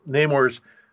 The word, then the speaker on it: Namor: By the way, I think the correct pronunciation is Namor, but as a kid, I didn't know how to pronounce it. So I turned to my mom and said, "Mom, how do you say this?" She looked at it, N-A-M-O-R. She said, "Hammer? Namor?" So I learned it as Namor, and I will occasionally slip.